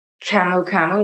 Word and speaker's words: camu camu: There's okra, camu camu, is that how you pronounce it? Camu camu? – Camu camu.